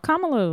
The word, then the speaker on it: Kamala: This was uh, before the election, but I'm gonna say her name wrong. Kamala, Kamala. – No, Kamala was right.